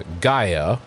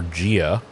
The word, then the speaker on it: Gaia: It's the root words are apogee, which is the furthest distance from, and then Gaia, which is, or Gia, which is depending on how you want to pronounce it, which is Earth.